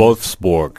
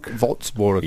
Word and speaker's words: Wolfsburg: Wolfsburg. – Wolfsburg. – Yeah, that's a German way. – Wolfsburg.